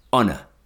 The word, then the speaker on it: honour: Honour. Honest. Air. Maybe now you remember the question. Oh yes, which words apart from our begin with a silent H in English? Only these three.